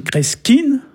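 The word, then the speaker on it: kreskiñ: le verbe kreski, hein, kreski, qui s'écrit kreskin, hein, on prononce pratiquement kreski.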